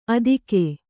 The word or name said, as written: ಆದೀಕ